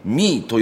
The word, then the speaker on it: ME: ヤンキマ、はい、やってまいりましたのが北陸道三条燕インターからおよそ 10分、漢字の 3 にハイフンで英語の ME ドットと書いてミーと読む、同じ施設の中になんか服とか駄菓子とかスイーツとか、ゲストハウスまであるという、一体何屋さんなんだろうという場所なんですけども、詳しくは、地域おこし協力隊の平野さんに聞いてみます。